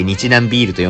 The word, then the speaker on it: 日南麦酒: そんなこんなでね、 6 本分はございますので、ゆっくりゆっくりね、えー、楽しみながら、えー、日南麦酒と書いて日南ビールと読むみたいですけど、この日南ビールシリーズをね、まずはちょっといただきたいなと思います。